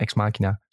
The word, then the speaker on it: Ex Machina: Of Ex Machina. Volgens mij is het gewoon Ex Machina. Zeg ik dat goed? Ik zie Sven knikken. Volgens mij klopt het.